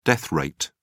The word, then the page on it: death rate: British English